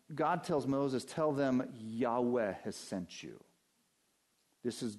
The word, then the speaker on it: Yahweh: And it's the term that popularly has been voiced as Jehovah. It's probably better pronounced Yahweh.